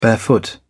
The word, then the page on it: barefoot: British English